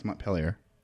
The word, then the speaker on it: Montpellier: I want to talk about Werewolves of Montpellier. Is that how you pronounce it? – Montpellier? – Montpellier? – No, it's Montpellier.